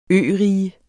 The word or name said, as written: ørige